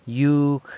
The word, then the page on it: yug: yoke